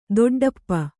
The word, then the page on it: ದೊಡ್ಡಿಪ್ಪೆ: doḍḍippe